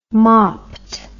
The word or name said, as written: mopped